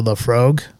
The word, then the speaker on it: Laphroaig: For our Scotch, we have the Laphroaig, or did I pronounce that wrong? – Sounds good to me.